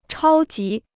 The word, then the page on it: 超级: chāo jí